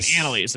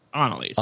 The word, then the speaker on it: Annalise: I was saying Annalise at the beginning, but that's very American. It's Annalise.